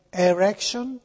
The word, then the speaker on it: Erection: Mi dice Giulia mi scrive, che ne dici delle gomme da masticare Erection? Pronuncia erection, cioè erezione.